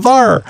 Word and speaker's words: Thar: T-H-A-R, that's a Thar, right? It just sounds Southern. – Yeah. Sounds like it, yeah. – It's kind of like if Thor went down south. – Exactly. It's over Thar.